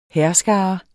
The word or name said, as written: hærskare